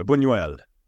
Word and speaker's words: Buñuel: It's got the tilde, so Buñuel.